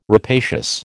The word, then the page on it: rapacious: American English